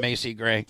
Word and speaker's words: Macy Gray: Is that how you pronounce her name? – Mm-hmm. – Macy Gray.